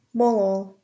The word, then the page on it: molal: /ˈməʊləl/